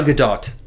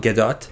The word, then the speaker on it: Gadot: But everyone says Gal Gadot. – Yeah. – Right? Because that's the way you're actually supposed to pronounce it. That's the Israeli pronunciation.